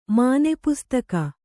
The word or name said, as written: ಮಾನೆಪುಸ್ತಕ